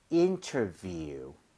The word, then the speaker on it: interview: 발음 주의하셔야 되는 게요. 거기 view이기 때문에 네. – V는 입술이 붙으면 안 됩니다. – 네. – So 아랫입술 위에 윗니 살짝만 올리고 바람을 뿜어주세요. 자, 갑니다. View, interview.